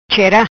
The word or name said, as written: Chera